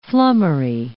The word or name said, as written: flummery